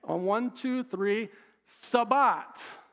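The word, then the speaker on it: Sabbat (שבת): And that word is the word Sabbath. Now, in the Hebrew, the word is pronounced Sabbat. Sabbat.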